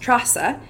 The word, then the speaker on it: Treasa: So it's an old, old Gaelic word, which means strength. And in Gaelic, E and A together is A. So it's spelled T-R-E-A-S-A, but pronounced Trasa.